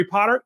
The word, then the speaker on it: Potter: My name is Jerry Potter. Yes, it rhymes with the boy wizard.